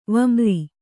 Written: ವಮ್ರಿ

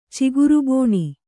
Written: ಚಿಗುರುಬೋಣಿ

